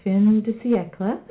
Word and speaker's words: fin de siècle: I think that it's fin de siècle, a French term, end of the century.